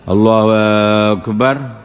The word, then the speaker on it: الله أكبر: فلا يمد الألف لا في اسم الجلالة ولا في اسم لفظ أكبر، يقول الله أكبر،